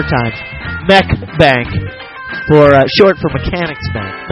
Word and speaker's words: Mech Bank: M-E-C-H-B-A-N-K. Mech Bank. – Mech Bank. How do you say it? – Mech Bank. All one word. – How about just one more time? – It's Mech Bank. One word.